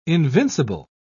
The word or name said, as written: invincible